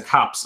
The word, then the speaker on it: copse: There's like these little, I don't know, whatever it's a copse of trees, right? Is that how you say it? C-O-P-S-E? – Yeah.